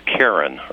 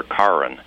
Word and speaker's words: Karen: They speak a language called, and you can pronounce this a lot of different ways, Karen or Karen, like the woman's name, K-A-R-E-N.